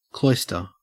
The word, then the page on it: cloister: Australian English